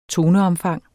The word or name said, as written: toneomfang